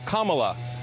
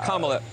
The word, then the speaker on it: Kamala: Her name is pronounced comma, like the punctuation mark, look, Kamala.